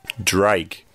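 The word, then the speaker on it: Draig: Drake. – Ah, okay. – Drake. So he said dry, like if something is dry, not wet, it's dry, and then put a G at the end.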